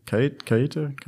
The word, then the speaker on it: Keita: So my man is named, I'm gonna call it Keita because I can't pronounce that bullshit. It's K-E-I-T-A. It's like, it would be like, it would be Keita, right? Keita? – What? – His name. Yeah, K-E-I-T-A.